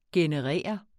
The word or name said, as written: generere